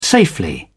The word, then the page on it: safely: British English